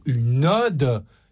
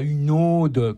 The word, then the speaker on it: ode: Ils ne savent pas prononcer, comme des millions de gens actuellement, le mot « une ode ». Une ode, ODE, ça se dit avec un O ouvert, et pas une ode, comme on entend dans le film, comme le prénom, avec, comme si c'était un O fermé.